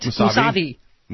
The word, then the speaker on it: Mousavi: Mousavi? Did I say it right? – Mousavi.